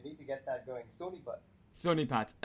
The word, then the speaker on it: Sonipat: Is that how to say it? Sonipat, is that right? – Yeah, Sonipat. Come on.